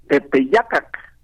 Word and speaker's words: Tepeyac: Tepeyacac, dicho en agua. En español decimos Tepeyac lo castellanizamos la pronunciación.